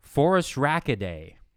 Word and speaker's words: Phorusrhacidae: Phorusrhacidae.